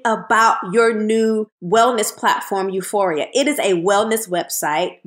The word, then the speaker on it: U4IA: Well, this next move, ladies and gentlemen, that she has made ain't going to be too silent because she is the founder and CEO of U4EA, but it's spelled U4IA.